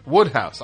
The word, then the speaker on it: Wodehouse: Number 86, Bernie Wooster from the P.G. Wodehouse? Is that how you say it? Woodhouse.